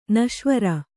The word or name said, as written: ನಶ್ವರ